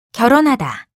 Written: 결혼하다

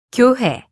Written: 교회

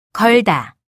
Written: 걸다

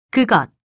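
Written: 그날

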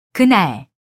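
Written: 그림

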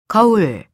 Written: 거울

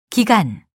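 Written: 기르다

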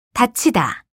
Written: 닫히다

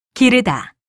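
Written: 긴장되다

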